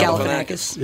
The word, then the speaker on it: Galifianakis: Is it Zach Galifianakis? I can never pronounce his name. – Galifianakis.